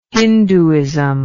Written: hindooism

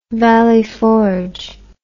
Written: Valley Forge

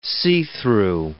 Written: see-through

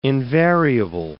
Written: invariable

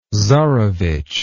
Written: tzarevich

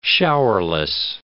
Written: showerless

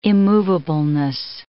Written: immovableness

